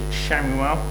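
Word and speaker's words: Shamuel (שמואל): The name Samuel in the Hebrew is, you pronounce it Shamuel, and there's a couple of different suggestions about what this actually means.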